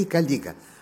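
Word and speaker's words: caliga: От Калига или Калига, скорее Калига. Рим, латынь не любит ударения на первом слоге.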